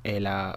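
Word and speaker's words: L.H.O.O.Q.: que si lo pronunciamos en francés es algo así como LHOOQ, algo así como ya tiene el culo caliente, que fue el título que Marcel Duchamp le dio a su particular relectura o remix de la Gioconda.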